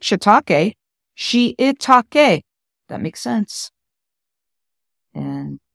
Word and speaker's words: shiitake: We often say shiitake mushrooms. It's shiitake. I think I learned the proper pronunciation from Ming Tsai, the chef. Ming Tsai, he says shiitake.